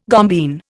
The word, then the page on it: gombeen: Indian